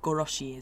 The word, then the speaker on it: goroshi: And then koroshi, or goroshi is the pronunciation of this word, means to kill.